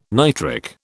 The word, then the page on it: nitric: British English